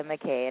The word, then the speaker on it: McKay: This is Erica McKay, and if you'd like to reach out to me, you can do so on Facebook.